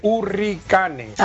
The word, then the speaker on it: Hurricanes: Estos los imitan, forman un conjunto, aunque ellos, su norte y su guía era un conjunto yankee llamado Johnny, creo que se pronunciaría Hurricanes, porque está escrito con H, Hurricanes.